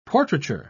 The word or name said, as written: portraiture